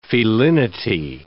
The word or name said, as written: felinity